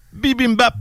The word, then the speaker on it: bibimbap: Ça s'écrit B-I-B-I-M-B-A-P. Bibimbap.